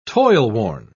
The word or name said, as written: toilworn